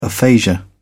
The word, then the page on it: aphasia: British English